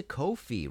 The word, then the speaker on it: Ko-fi: If you like this show and want to support future episodes, please go to Ko-fi, coffee rhymes with no fee.